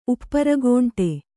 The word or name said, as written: ಉಪ್ಪರಗೋಂಟೆ